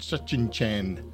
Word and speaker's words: Tsuchinshan: It's spelt TSUCHINSHAN, Sachin Chan Atlas, certainly fits the bill.